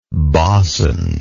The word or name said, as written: bausond